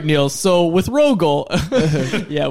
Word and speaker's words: Rögle: Rögle.